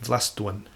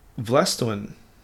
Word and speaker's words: Vlastuin: Now, Vlastuin, or Floston, I think they call it, he is the pronunciation, had 1 mark for the game.